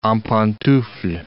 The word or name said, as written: en pantoufles